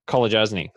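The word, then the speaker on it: Kolodjashnij: what's his name again? I can't pronounce it. – Who? – Colajasny. That's his name.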